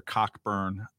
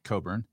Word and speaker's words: Cockburn: I used to work with Alexander Cockburn, whose name I pronounced like that on purpose. – Well, what is it supposed to be pronounced like? – Coburn. – Then spell it that way, dude.